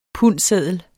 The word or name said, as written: pundseddel